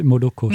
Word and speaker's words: Moloko: c'est pas Maloko aussi ? – C'est Moloko. – Moloko, pardon. Désolée pour la prononciation.